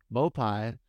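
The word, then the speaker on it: Maupay: And then Maupai, how do you say this guy? Dude, I cannot say this guy's name. – Maupai is suddenly becoming interesting, but you don't know, you know.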